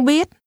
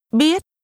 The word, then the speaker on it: biết: Tôi không biết. – Repeat after the speaker. – Tôi không biết. Biết. Biết. – The Vietnamese word no is pronounced with a high rising tone.